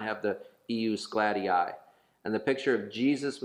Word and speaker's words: ius gladii: Yes, EUS is IUS, and it's GLADII, three syllables. It's G L A D I I, IUS GLADII, which is Latin for the right of the sword.